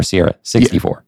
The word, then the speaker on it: Sierra 6.4: It's Sierra 6.4 or Sierra 64? – Yeah. Sierra 6.4.